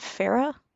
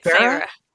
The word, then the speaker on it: Feyre: Is it Farah or something? – Fera. – Farah. – Yeah, I was saying it as Feyre.